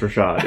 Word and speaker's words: Rachaad: Is it Rashad? Because it's spelled Rashad. – I think it's pronounced Rashad, yeah. – Because it is Rashad. R-A-C-H-A-A-D or something like that?